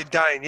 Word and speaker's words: Dyn: Uh, um, uh, it goes by Dine.